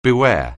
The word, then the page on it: beware: anglais